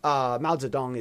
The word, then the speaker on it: Mao Zedong: Mao Zedong is how you say his name in Chinese. If you say Mao Zedong, no one has any clue what you're talking about over here.